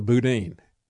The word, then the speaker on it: Boudin: It's just like Jethro Boudin, right? I didn't spell it that way, but Chesa Boudin.